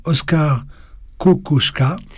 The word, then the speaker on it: Oskar Kokoschka: Tout d'abord, alors si j'écorche un peu le nom, il faut me reprendre, hein, celle consacrée à Oskar Kokoschka. – C'est ça. Hein, c'est ça, c'est bon.